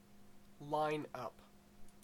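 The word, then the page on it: lineup: Canadian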